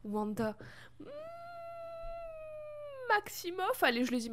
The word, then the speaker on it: Wanda: mais il s'agit de façon très évidente de Vanda Maximoff, Wanda Maximoff, peu importe comment on le prononce, mais à l'anglais c'est Wanda qui disent.